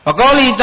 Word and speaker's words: wa qawlihi: Wa qawlihi ta'ala, bacanya wa qawlihi ya.